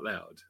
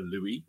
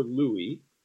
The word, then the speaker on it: Lou: So Lou or Louis. – Okay. – But generally, Lou is fine.